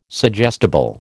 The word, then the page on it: suggestible: American English